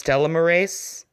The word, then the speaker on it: telomerase: It's T-E-L-O-M-E-R-A-S-E.